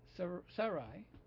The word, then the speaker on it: Sarai: Hence, Sarai, with the emphasis on the first syllable, said to Abram, please now, Jehovah has shut me off from bearing children.